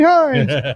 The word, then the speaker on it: Hintz: Originally, it was Hines Ketchup, but it's spelled H-I-N-T-Z. – Yeah. Hey, everybody, get behind Rupee Hines.